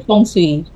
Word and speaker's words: feng shui: Actually, feng shui. Feng shui, feng shui nga, feng shui.